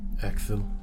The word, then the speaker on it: Ecthel: My name is Ekthil. – Ekthil? – Yes. – It's spelled E-C-T-H-E-L.